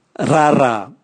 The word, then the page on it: rara: Raga